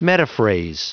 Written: metaphrase

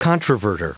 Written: controverter

